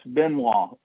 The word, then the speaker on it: Benoit: Benoit.